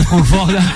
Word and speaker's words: Frankfurt: Allez, c'est Frankfurt.